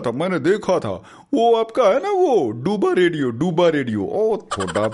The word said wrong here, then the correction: ਦੁਆਬਾ: ਭਲਾ ਜੇ ਮੈਂ ਕਿਹਾ ਵੀਰੇ ਡੂਬਾ ਨਹੀਂ ਵੀਰੇ ਦੁਆਬਾ ਦੁਆਬਾ ਹਾਂ